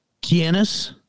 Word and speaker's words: Keinys: How do you say that last name again? – Kianis. – What's spelled? – Kianis. – Penis? – Kianis. I would Kianis. K-E-I-N-Y-S. – Okay. – Christupas. It's K-R-I-S-T-U-P-A-S is the first name.